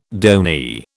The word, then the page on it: donee: British English